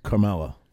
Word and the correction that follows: Kamala: How are you pronouncing her name? Carmella. I'm trying to be disrespectful, but isn't it Carmella? I think it's Kamala. Is it? – Kamala. – Okay, sorry. Sorry, Kamala.